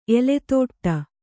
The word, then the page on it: ಎಲೆದಟ್ಟೆ: eledaṭṭe